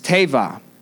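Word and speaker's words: tebah: It's T-E-B-A-H, and it's teva.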